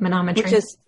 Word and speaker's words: manometry: And when I went to the hospital, I believe they did, it was so long ago, they did the mammometry. Am I saying it right? – Yep. Manometry.